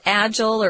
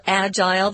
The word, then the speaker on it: agile: Tom mentioned we have two pronunciations for this word. It's like fragile. You can either say fragile or fragile. Here it's agile or agile. They're both correct.